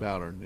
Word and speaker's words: Bowdern: Is that the correct pronunciation? – Sounds like it. – So. Bowdern, yes.